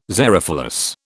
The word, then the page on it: xerophilous: British English